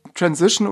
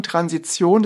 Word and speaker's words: Transition: Der korrekte Begriff ist tatsächlich äh, Transition oder auch deutsch ausgesprochen Transition.